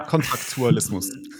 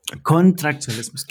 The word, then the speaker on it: Kontraktualismus: Kontraktualismus. Kontraktualismus, genau.